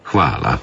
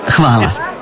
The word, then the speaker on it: Hvala: Hvala, to je osnovno. Kako izgovarate? – Hvala. – Habala? Thank you, yes. Okay, hvala.